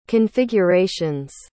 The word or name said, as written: configurations